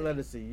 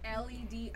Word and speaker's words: Ledisi: She is a neo soul artist, but her name is not Lettucey. You said it wrong. – It's Lettucey. – That sounds wrong. – It's Ledisi. I am a fan.